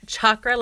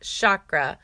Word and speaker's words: chakra: It's chakra like chair, not chakra like shimmy.